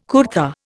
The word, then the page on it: kurta: Indian